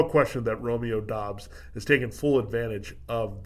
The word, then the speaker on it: Doubs: It's Romeo Doubs here. Worthy buzz, and that's how you say his name, Doubs versus Dubs. Whatever you call it, Doubs, Dubs.